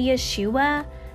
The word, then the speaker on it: Yeshua: And just a little caveat here, when you hear me say Yeshua, that's the Hebrew pronunciation of his name.